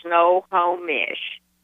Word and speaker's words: Snohomish: I understand it's pronounced Snohomish. Did I get it right? – It's Snohomish. It's just like it's spelled, S-N-O-H-O-M-I-S-H.